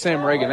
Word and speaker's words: Ragan: I think it's Sam Reagan. That's how I've got it written down, R-A-G-A-N. – Okay. – So that's how I would pronounce it.